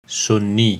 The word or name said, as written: Sunni